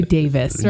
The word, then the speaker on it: Davies: Right, or Ray Davis, right? – Yes, right. We were going to have a big argument about how to pronounce his name because that's just podcasting gold. – Right. And so the argument is Davies or Davis. There mm-hmm. you go. End of argument.